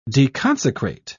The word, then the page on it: deconsecrate: British English